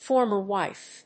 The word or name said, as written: former wife